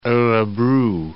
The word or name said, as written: orebro